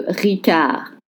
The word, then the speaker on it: Ricard: Le Ricard. Don't pronounce the D at the end.